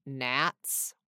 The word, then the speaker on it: gnats: Do you think he also met some gnats, which also starts with the letter G?